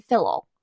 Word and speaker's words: Fillol: And she happened to have an older brother named Edward, who was married to this lady named Catherine Fillol. I believe it's pronounced like that. It's spelled F-I-L-L-O-L, so I imagine Fillol is the correct pronunciation.